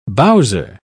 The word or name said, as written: bowser